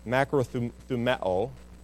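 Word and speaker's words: Makrothueo (μακροθυμέω): Makrothueo is how it's said.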